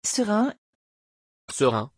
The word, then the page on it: ceren: French